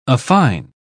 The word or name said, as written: affine